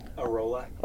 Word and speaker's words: Arola: Arola. – Arola. Like, you can roll a Rolo to you, except right, Arola?